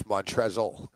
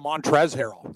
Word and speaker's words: Montrezl: There's an L at the end of that, huh? – Yeah, I've heard it both. I think it's everyone talks, they go Montrez Harrell. Like, they don't even pronounce the L, but it's spelled that the other way.